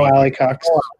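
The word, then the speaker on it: Mo Alie-Cox: Mo Alley Cox.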